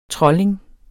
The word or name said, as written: trolling